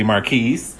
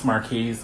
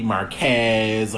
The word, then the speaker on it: Markiz: Um, my name is spelled M A R K I Z and it's pronounced Marquise. Um, a lot of people have a hard time with that. They usually would pronounce it like M A R K E Z Marquez or Marcus. It's just Marquise.